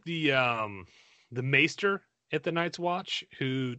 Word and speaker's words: Maester: I refuse to call them Maesters. – Maester, M-A-E, Maester.